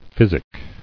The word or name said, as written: Physicking